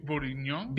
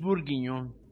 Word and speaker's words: bourguignon: Beef bourguignon. – Beef bourguignon.